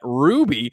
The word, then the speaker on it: RWBY: We are currently creating a RWBY, I believe they pronounce that, Ruby, outdoor mural that you can add your art to at home using the Mark Augmented Reality app.